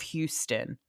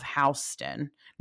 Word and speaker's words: Houston: Houston. It's south of Houston. No one calls Houston, Houston. It's spelled like Houston, but it's Houston Street.